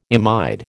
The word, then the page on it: imide: American English